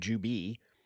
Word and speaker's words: Jubei: So I say Jubei as well. It's pronounced both in the movie. Some people say Jubei and some people call him Jubi.